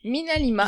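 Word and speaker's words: MinaLima: D'ailleurs, Mina Lima, c'est ça, si je oui. – prononce pas... – Mina Lima, ils sont exactement.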